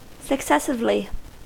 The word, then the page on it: successively: American English